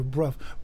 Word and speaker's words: Brough: Bruff. But, you know, spell that. Her name is spelled B-R-O-U-G-H. – Yeah, so Ruff with a B. – Yeah, so Bruff.